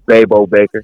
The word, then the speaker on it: Baybo Baker: Yeah, it's Baybo Baker.